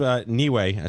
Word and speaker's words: Neway: So Dave Neway, I said that right.